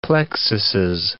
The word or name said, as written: plexuses